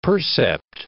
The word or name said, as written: percept